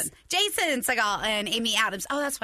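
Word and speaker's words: Segel: And I loved, like, the new movie that came out in 2011, The Muppets, with Adam Segal and wait, did I say that right? – No. – And Amy Adams. Who's the main guy? – Seagal is right.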